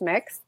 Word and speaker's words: Mx: MX is usually the kind of Mr. and Mrs. version. It's pronounced mix.